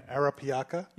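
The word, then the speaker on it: Arapiraca: It's a now you're going to have to help me again with the pronunciation. It's a Brazilian Arapiaca. – You nailed it.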